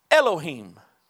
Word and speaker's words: Elohim: And so Moses uses a Hebrew word that we would pronounce Elohim. E-L-O-H-I-M. Elohim.